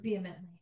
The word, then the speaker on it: vehemently: against which the stream beat vehemently. How do you say that word? – Vehemently. – Vehemently.